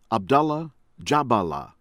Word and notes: Abdallah Djaballah: American English

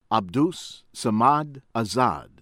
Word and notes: Abdus Samad Azad: American English

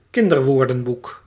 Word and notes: kinderwoordenboek: Dutch

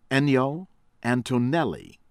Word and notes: Ennio Antonelli: American English

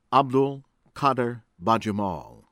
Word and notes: Abdul Qaddar Bajamal: American English

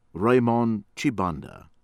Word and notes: Ramond Tshibanda: from Congo-Kinshasa

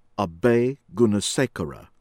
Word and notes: Abeygoonesekera: American English

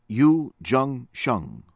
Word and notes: Yu Zhengsheng: American English

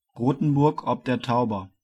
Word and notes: Rothenburg ob der Tauber: German

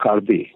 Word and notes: Karbi: Karbi-language

